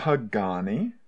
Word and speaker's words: Pagani: Pagani.